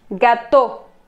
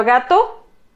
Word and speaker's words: gâteau: pero suena un poco como gato, gato, pero gato, el animal, no tiene la misma pronunciación, porque si se fijan, gato, un gato, y en francés es gâteau, como si hubiera un acento al final de la pronunciación.